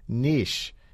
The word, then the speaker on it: niche: And almost immediately, we got a complaint from a listener who commented on our website, quote, "For centuries, The word N-I-C-H-E has been pronounced to rhyme with itch by English speakers.